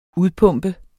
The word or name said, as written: udpumpet